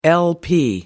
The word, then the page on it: l-p: American English